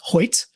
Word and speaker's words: Hoecht: Yeah, and I found out it's pronounced Hoyt. Hoyt. Which I don't know. H-O-E-C-H-T makes a Y sound, but go on. However, from what I understand, it's Hoyt.